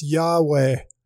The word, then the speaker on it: Yahweh: In Hebrew, it's four letters, YHWH, pronounced Yahweh, Jehovah, the covenant-keeping God who is.